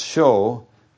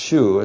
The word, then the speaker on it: shew: And he says, show, and by the way, the word, the Old English spelling of show is S-H-E-W, and it's used here in connection with Philip's statement in verse 8, but it's not pronounced shu, it's pronounced sho, and somewhere along the line the E was changed to O, but it's the same word, that our word sho.